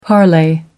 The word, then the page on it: parley: American English